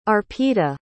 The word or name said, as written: Arpita